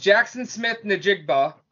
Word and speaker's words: Jaxon Smith-Njigba: He finds Jaxon Smith-Njigba.